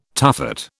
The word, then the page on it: tuffet: British English